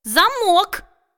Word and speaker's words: замок: Но как все-таки правильно? Замок или замок? – Правильно и так, и так.